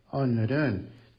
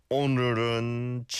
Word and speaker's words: 오늘은: I think the leal on the null, 오늘은, was a little bit more like an L than it should be, but that's just me nitpicking.